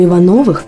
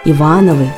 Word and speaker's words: Иванов: Ивановы – люди совсем другого жанра. Это известные писатели, композиторы, генералы или государственные деятели. Например, писатель Всеволод Иванов, поэт Вячеслав Иванов. У них у всех ударение падает на второй слог.